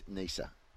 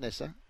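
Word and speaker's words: Neser: You know, we've been really excited by what we've seen from Jai Richardson for a few years now, and Michael Nesser, I'm really excited for him. He's worked his backside off. – The pronunciation is Nisa. Not Nessa, Nisa.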